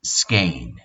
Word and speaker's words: skein: Madeja in English means skein, and it's pronounced like Spain but with a K.